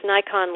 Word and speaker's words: Naikan: It really flips your perspective, and that's based on a really interesting Japanese psychology technique that's called Nikon, which is it's pronounced Nikon like the camera, but it's spelled Naikan.